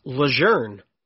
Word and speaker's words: Lejeune: It's L-E-J-E-U-N-E. How would you pronounce that? L-E-J-E-U-N-E. – I would say Lejeune. – Lejeune. That's how everybody says it across the state, right? – Yeah.